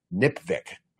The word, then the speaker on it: NPVIC: I introduce a way to pronounce it, which I've never heard anywhere else, NPVIC, which rhymes with lipstick.